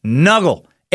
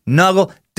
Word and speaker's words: Nugl: Find the best customer service, best weed-related products on Nuggle. N-U-G-L. That's how it's spelled. N-U-G-L. Nuggle.